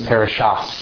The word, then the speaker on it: parashat: Ashkenazi is going to have the same letters, but they pronounce like the Tav, that would be Toldot for them, that would be Toldos. They pronounce that with an S. Parashat for them is parashas. Spelt the same, but they pronounce things differently.